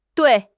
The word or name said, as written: dui4